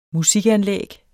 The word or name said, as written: musikanlæg